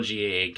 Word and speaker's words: GAA: I will wonder, we call GAA GA, like GAA, like we just pronounce it like that.